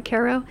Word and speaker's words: Cairo: And we are in the hamlet of ACRA, New York, which is inside the town of Cairo, New York, spelled like Cairo, pronounced unfortunately like Cairo, and we're in Greene County.